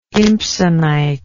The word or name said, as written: impsonite